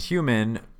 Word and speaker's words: HVMN: It's spelled with an all caps H-V-M-N, but pronounced Human.